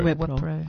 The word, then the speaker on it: Wipro: Wipro.